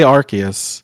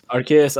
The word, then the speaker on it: Arceus: Or Arceus. Yeah. They say Arceus. – Arceus, okay. – Pokemon in English. – Arceus. – Which I guess I've been saying it wrong this whole time, which makes me feel some sort of way, but whatever.